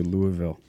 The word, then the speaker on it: Louisville: I love how you say it, too. Louisville. – Yeah. – That's the right way to do it, right? – You get, like, molasses with it.